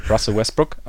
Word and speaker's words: Russell Westbrook: Russell Westbrook.